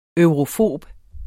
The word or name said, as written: eurofob